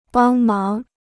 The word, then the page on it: 帮忙: bāng máng